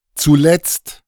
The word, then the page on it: zuletzt: němčina